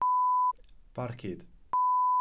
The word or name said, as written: barcud